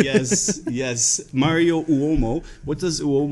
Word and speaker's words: Uomo: So is it Uomo? – Yeah, well, it's Uomo, but most people say Uomo.